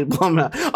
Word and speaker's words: Blumhouse: Because it's spelled B-L-U-M and that looks like Blum. It rhymes with cum. – And plum. – You wouldn't call it Coomhouse. We would call it Coomhouse, but this is definitely Blumhouse. Oh, yeah.